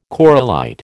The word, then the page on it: corallite: American English